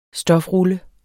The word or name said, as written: stofrulle